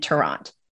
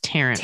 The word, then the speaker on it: Tarrant: and Tarrant. – I think it's pronounced Tarrant. – Tarrant. Okay. I think you're probably right. You know, Texas.